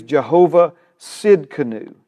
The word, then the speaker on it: Jehovah Tsidkenu: The T is silent, the best of my Hebrew transliteration pronunciation experience understands. Amen. He is Jehovah Tsidkenu.